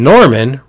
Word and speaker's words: Norman: And the head honcho there, who also directed the film, was a guy named Ross Norman. And Norman rhymes with Corman, as in Roger Corman, who was the head of New World Pictures.